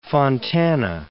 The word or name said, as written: Fontana